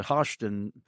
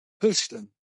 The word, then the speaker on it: Houston: Now, how did you pronounce it? Because I've been calling it Houston for years. And nobody's corrected. – They pronounce it Houston, like hush, Houston.